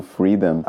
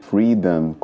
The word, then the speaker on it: Freedom: Oh, Freedom. Apesar de escrever Freedom com O M no final, a gente não pronuncia freedom, né? Mas fala freedom, como se fosse com um A mesmo no final, né?